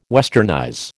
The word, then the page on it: westernize: American English